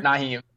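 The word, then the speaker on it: Nyheim: But they've had Nehemiah Hines. I should have probably said that wrong. But Hines has stepped in, man. And Naheem Hines. I get it there? – Naheem, yeah.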